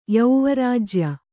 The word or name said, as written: ಯೌವರಾಜ್ಯ